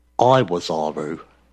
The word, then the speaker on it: Iwazaru: Iwazaru. – Oh, Iwazaru? – Okay, yeah, it could Iwazaru. Be Iwazaru. Yeah, yeah, yeah, I mean, it'd be spelled with an I, but pronounced like that.